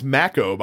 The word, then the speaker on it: Mako: Uh, it is you descending into the first uh, Ma- apparently it's pronounced Mako by the way. Never knew yeah. that for for fucking years.